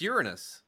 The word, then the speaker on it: Uranus: It's Uranus.